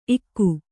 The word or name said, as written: ಇಕೇ